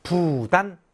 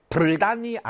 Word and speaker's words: 부단: 왜? 불단이 아닌지는 아시죠? 뒤에 보통 이렇게 따라오는 한자의 초성이 디귿이거나 지으실 경우는 발음하기가 조금 어렵단 말이죠. 불단 하는 순간 침이 팍 튄단 말이죠. 그래서 발음을 편하게 하기 위해서 리을을 탈락시켜 보통은 불로 읽는다.